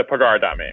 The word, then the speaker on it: Pagar.me: Is it Pagar.me or Pager.me? – It's a Pagar.me.